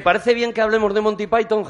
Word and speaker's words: Monty Python: Es Monty Python, es Monty Python y mucha gente los llama los Python nada más, de Pythons.